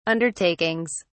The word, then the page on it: undertakings: British English